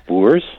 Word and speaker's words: Boer: It's pronounced Boers. – Yes. – And it's a Dutch word meaning farmer, but it's true.